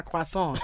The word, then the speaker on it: croissant: Come on, how do you say it? – Croissant. – Voilà.